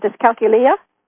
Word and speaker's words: dyscalculia: Anyway, the other thing I learned from your book was that there is a form of dyslexia called dyscalculia. How do you pronounce it? Dyscalculia? – Dyscalculia, yeah.